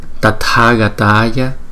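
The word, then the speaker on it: Tathāgatāya: Chữ T với chữ H này thường thường chúng ta đọc là thờ nhưng mà nó là tờ, tờ, tờ, tờ. Tathagataya, Tathagataya.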